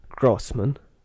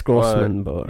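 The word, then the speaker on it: Großmann: And his name's Carl Grossman, or Grossman. – Yeah. – He's German, so it's spelt differently. It's like, it's spelt Grobman. – Yeah. Oh, it's the... – Yeah. It's still it's Grossman, it's the double S.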